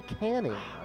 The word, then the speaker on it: Canning: I thought it was Channing. – Griffin does it. – It's actually Canning. – Another correct. – How do you spell it? – C-A-N-N-I-N-G.